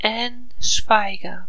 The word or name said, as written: Ann Schweiger